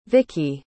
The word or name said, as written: vicy